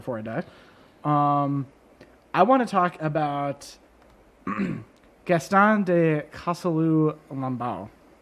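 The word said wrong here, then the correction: Chasseloup-Laubat: Is that how you pronounce that? – Gaston de Chasseloup-Lubal.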